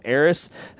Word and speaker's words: Aeris: So for the hardcore nerds out there, there is two pronunciations of her name, Aeris and Aerith.